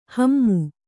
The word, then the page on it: ಹಮ್ಮು: hammu